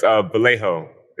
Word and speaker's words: Vallejo: Is that Velo? – It's Vallejo. It's kind of Vallejo. – Strange. – Vallejo. – Yeah, Vallejo. Yes. – Excuse me. Vallejo. – No, it's a strange name, and it's spelled Vallejo, so it trips everybody up.